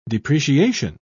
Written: depreciation

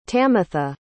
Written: tamatha

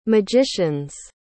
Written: magicians

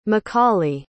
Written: macaulay

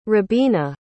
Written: robina

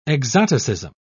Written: exoticism

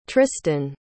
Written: tristin

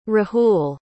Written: rahul

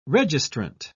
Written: registrant

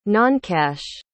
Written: noncash

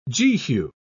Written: Jehu

